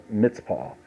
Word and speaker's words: Mizpah: And Samuel called the people together unto Yahweh to Mizpah, or Mitzpah, it's pronounced.